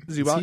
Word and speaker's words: Zubac: How do you say his name? – Zubac.